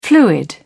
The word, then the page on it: fluid: British English